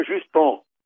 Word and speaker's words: Justin: porque pronuncia um Justin, o sujeito é Trudeau, portanto ele é Juston.